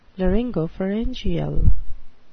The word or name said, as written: laryngopharyngeal